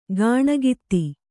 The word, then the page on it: ಕಣ್ಗೆತ್ತು: kannada